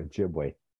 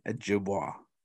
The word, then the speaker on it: Ojibwe: The Ojibwe. – A.K.A. Ojibwa. How come people say Ojibwa? Like, they don't put a Y at the end. It's just W-A. – It's different pronunciation. – Ojibwe, Ojibwa. – Accents on the vowels. It's just A-E-I-O-U.